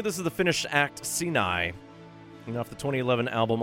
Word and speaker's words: Siinai: So from 2016's My Best Human Face, this will be Moonface along with Sinai. That's spelled S-I-I-N-A-I, Sinai.